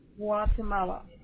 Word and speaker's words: Guatemala: And how is that pronounced? – Guatemala. – Guatemala, okay. Perfect.